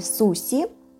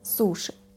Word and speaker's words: суши: «суши», которое еще некоторое время имело фонетические варианты. Можно было услышать его в произношении «суси», приближенное к японскому произношению, но все-таки сохранилось сейчас уже устоялось как произносительный вариант «суши».